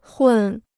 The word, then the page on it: 混: hùn